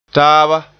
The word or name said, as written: taaba